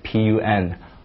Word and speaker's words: pun: 双关语 p u n pun，对吧？你不能读成胖。